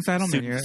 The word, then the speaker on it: Seidelman: Directed by Susan Seidelman. Did I pronounce that right? – Susan Seidelman.